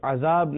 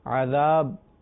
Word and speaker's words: عذاب: اللہ تعالی ہمیں محفوظ رکھے, اس کا تلفظ درست ہونا چاہیے, اسے عذاب نہیں کہیں گے, عذاب پڑھیں گے.